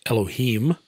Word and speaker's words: Elohim: Pronunciation is Elohim.